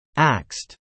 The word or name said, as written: axed